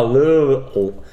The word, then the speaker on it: Louisville: Ah, Louisville. You have to say it like you're swallowing Louisville.